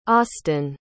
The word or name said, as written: austin